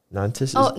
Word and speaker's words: Nantes: Nantes? – Nantes.